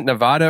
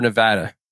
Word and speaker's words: Nevada: in, do you pronounce it Nevada or Nevada? – It's supposed to be, I think it's supposed to be Nevada. So if you say Nevada around any Nevadians here, they'll probably beat you up.